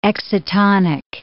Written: excitonic

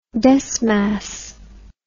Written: desmas

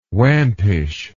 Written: wampish

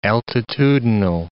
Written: altitudinal

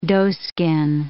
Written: doeskin

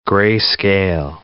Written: grayscale

